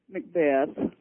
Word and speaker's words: McBeath: It's M-C-B-E-A-T-H. It looks like McBeath. What should it be? – Macbeth. – Oh, really? – It's just like death.